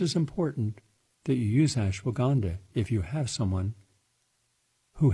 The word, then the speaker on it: ashwagandha: Well, what they're finding is ashwagandha. Let me spell it for you. A-S-H-W-A-G-A-N-D-H-A, ashwagandha.